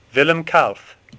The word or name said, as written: Kalf